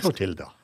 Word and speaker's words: Clotilda: Claudia Clutias, Clutias, in On Fire. Eller er. Clotilda. Clotilda. Ja ja, sånn kan du uttale det.